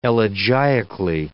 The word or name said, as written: elegiacally